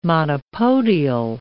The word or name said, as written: monopodial